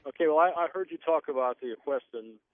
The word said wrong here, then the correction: Questran: That's Questran. – Questran. – Equestran. How do you say it again? – Q-U-E-S-T-R-A-N.